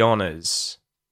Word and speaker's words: honor: Even though it starts with an H, the H is silent. So it's honor or honors. Honors, honors. Honor, we would pronounce the O U R at the end of the word honor as an uh sound in Australian English. But as soon as it becomes plural with the S, it becomes a schwa vowel sound instead of an uh vowel sound.